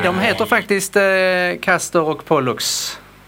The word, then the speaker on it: Pollux: Jag påstår att de båda stjärnorna i tvillingarnas stjärnbild heter Castor och Pollux, tror jag man uttalar det. – Rätt.